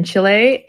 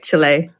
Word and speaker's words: Chile: Yeah, so Cote was born in Chile, and I am saying that right, I believe. Chile, right? Are you all with me? I want to make yes. – sure. Okay, Chile. Not Chile, like we say in Oklahoma.